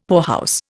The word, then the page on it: poorhouse: Indian